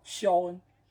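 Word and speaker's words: Sean: 但是其实这个读法应该是叫肖恩，对吧？这个 S E N 这个词其实一直就是被读作肖恩的。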